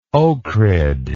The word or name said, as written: ohrid